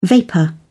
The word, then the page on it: vapour: British English